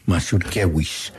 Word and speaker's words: Mazurkiewicz: Y bueno, estaba Mazurkiewicz. – Mazurkiewicz.